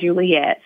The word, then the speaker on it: Juliet: So you can go there, and my name is spelled just like Romeo and Juliet. So one E, one T.